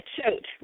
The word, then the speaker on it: Choate: It's kind of a weird one. It's Choate. – Choke? – Yeah, Choate. Rhymes with goat.